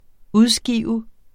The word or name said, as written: udskive